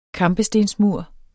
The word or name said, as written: kampestensmur